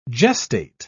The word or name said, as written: gestate